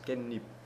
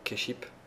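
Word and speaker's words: kenip (깻잎): Et des feuilles de sésame qui se disent kenip. – Oui, à ne pas prononcer keship. – Oui. – C'est une exception.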